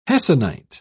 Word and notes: hessonite: British English

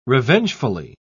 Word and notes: revengefully: British English

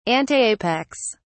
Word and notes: antapex: British English

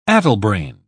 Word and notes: addlebrained: British English

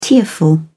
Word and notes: tearful: British English

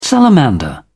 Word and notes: salamander: British English

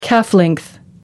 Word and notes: calf length: American English

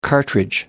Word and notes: cartridge: American English

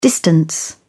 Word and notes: distance: British English

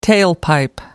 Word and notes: tailpipe: American English

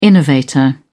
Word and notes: innovator: British English

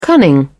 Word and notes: cunning: British English

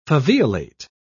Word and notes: faveolate: British English

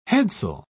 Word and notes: headsail: British English

